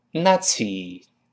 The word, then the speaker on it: Nazi: 你想再举几个例子，像纳粹 nazi，我们读成 nazi，哎，是这么读的。